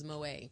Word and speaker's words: Moët: Is it the drink is it Moët or Moët? – I believe the correct pronunciation is Moët.